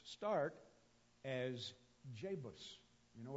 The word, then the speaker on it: Jebus: So when you see the J in Hebrew, it's almost always pronounced like a Y. It is Jebus, Jebus.